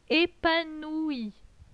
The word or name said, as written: Epanoui